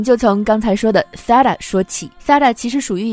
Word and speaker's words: Zara: 今天的话题是非常 interesting 的，因为前几天在和朋友逛街的时候，朋友说他想去那家西班牙的店买衣服，我就问他是哪家，他说 Zara。哎呀，和我做了这么长时间的朋友，仍然管这家店叫做 Zara 吗？它在西班牙语里的发音才不是 Zara 呢。 – Eh, cierto, en español deberíamos decir Zara.